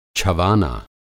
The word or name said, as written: छवाना